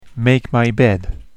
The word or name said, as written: make my bed